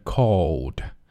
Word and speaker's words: called: Called, 注意这里啊。口型要逐渐收圆 called. And I called up our director and I said, Director, 注意要 r 它的发音 director.